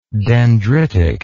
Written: dendritically

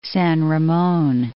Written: San Ramon

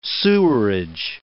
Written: sewerage

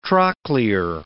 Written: trochlear

